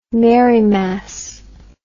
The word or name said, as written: Marymass